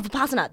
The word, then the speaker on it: Vipassana: I've also recently, I've been listening to some podcasts about, how do I say it? Vipassana? – Vipassana? – Oh, Vipassana. That's how you say it.